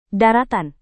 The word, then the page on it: daratan: Indonesian